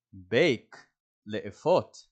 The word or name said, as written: bake